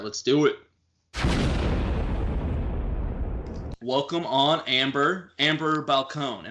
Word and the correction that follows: Balcaen: Am I saying that right? – Balcaen. – Balcaen? – Yep.